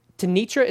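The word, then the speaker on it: Tenitra: Tanitra, is that how you say it? – You said it right, my friend.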